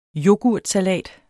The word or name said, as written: yoghurtsalat